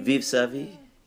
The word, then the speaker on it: Vivre sa vie: I cannot pronounce anything of yours, but Vivre sa vie? – Vive sa vie. Yeah, that's good.